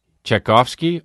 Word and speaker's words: Tchaikovsky: Thing to say, but during Czech Gorski. How do you say it? Tchaikovsky. Oh, well, that's an R. Tchaikovsky, right. I know Tchaikovsky.